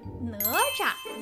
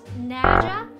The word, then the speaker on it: Nezha: And pay attention to the pronunciation here. It's Nezha in Mandarin Chinese, not Naja or Naja.